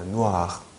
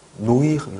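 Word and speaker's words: noir: 그래서 누아르, 네. 누아르, 네. 누아르 있잖아요. 한국말로도 많이 쓰이는 누아르. 이게 누아가요. 누아르, 누아르. 아시겠죠? 네. 노이르 이런 거 아니에요.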